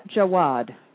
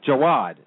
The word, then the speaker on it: Jaouad: I know how to pronounce the first name, but her name is Suleika, S-U-L-E. It's Suleika... – Here, the pronunciation, actually, the New York Times has the correct pronunciation. – What is it? – Suleika Jawad. – Jawad. Mm-hmm. J-A-O-U-A-D.